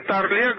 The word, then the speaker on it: Тарле: Тарле говорил, что моя фамилия всегда звучит с ударением на первом слоге.